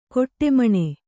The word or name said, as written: ಕಡೆಮಿಣಿ